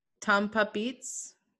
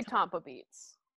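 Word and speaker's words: Tomppa: It's Tompa Beats. It's like T O M P P A B E A T S. How would how would you oh. pronounce that? – Like Tom, Tom, Tom yeah, Tompa Beats. – Tom, Tom, Tom, Tompa Beats. Tompa Beats. Tompa. I'm gonna say Tompa Beats.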